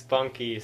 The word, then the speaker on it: Bunke: It's because it's spelled B-U-N-K-E, so you'd think it's silent, but it's not.